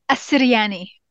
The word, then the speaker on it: Assiryani: It is Assiryani.